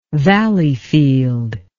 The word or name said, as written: Valleyfield